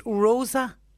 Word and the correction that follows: rosacea: On my face, red patches. – Rosacea. – Rosacea.